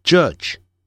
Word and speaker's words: judge: 好了，那么再来看法官 J 饿、肚子饿的饿 J，然后尾音也是这样的，念的时候念成 j，本来理论上念成 j，可是这个 j 在尾音的时候念成几，几乎的几。好，所以呢念得很短，几乎听不见 judge，应该是还是听得见了，就是说它不会有声音出来，几乎没有声音 judge，不要念成 judge 啊，不是这样念 judge Judge 变成没有声音了。